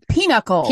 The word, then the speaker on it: pinochle: Often, when asked to join a family member for dinner out, Zulene's response was, "Can't tonight, we're playing Pinochet." Or not Pinochet, Pinochle. – Pinochle.